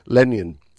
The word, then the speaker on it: Lannion: Filed a complaint last week after going for a haircut in a small Brittany town of Lannion, I think it's pronounced.